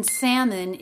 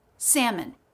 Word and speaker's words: salmon: The L in salmon is silent. Also, forget that O. Think of it maybe as an I. Salmon. Salmon.